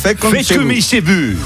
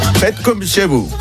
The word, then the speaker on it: Fait comme chez vous: Yo esto no quiero pronunciarlo, porque Faites come chebus, ¿cómo es esto? – Fait comme chez vous. – ¿Cómo es, que tú sabes francés? – Fait comme chez vous.